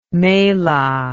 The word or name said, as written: mela